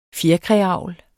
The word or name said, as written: fjerkræavl